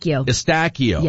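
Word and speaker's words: Eustáquio: Well, our local tie to the Canadian team is Stefan Estiguico. Is that how you pronounce it? – Estacchio. Yes.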